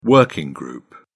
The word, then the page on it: working group: British English